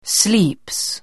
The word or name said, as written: sleeps